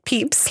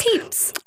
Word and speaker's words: Pepys: But it's P-E-Y-P-S, and that's how you say it. Pepys. – Pepys.